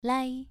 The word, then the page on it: lai: 2